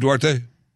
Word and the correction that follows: Duterte: Duterte. President Rodrigo Duterte.